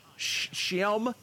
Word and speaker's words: Schim: The first one, I don't know how to pronounce this, Shim? C-H, or C, sorry, S-C-H-I-M. Shyam.